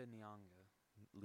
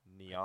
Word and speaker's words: Nyong'o: And the mom is played by, I don't know her real name, but Lupita Nyong'o. – Lupita what? – Nyong'o. – Nyong'o? – I think that's how you say it.